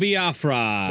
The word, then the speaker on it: Jello Biafra: Jello Biafra. That's how you say it.